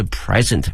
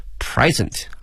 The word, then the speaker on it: present: 我们想到礼物啊有两个词，一个是 gift, G-I-F-T 可以哈，还有一个呢是 present P-R-E-S-E-N-T 这个词啊，如果表示礼物的话呢，它的发音是 present 啊，注意一下它的发音呢，重音在前面啊，present。如果这个词哈、啊、作为动词的话呢，则变成了 present，表示呢展示的意思哈、啊。